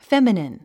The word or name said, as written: feminine